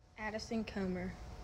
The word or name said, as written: Addison Comer